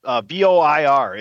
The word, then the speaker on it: Voir: Voir, it's French. Yeah, there you go. – Voir, like voir, like black noir, but with a V.